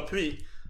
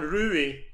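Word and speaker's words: Ruiz: Är, här uttalar du som du vill uttala Pui. Här säger man Rui. För det är spanska.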